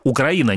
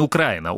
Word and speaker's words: Украина: Украина, не Украина. Украина, украинцы. Запомните, это правильно по-русски.